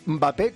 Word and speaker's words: Mbappé: A ver, puedes decir Mbappé, que empieza con M, no con E, o Mbappé, que te lo va a permitir cualquiera.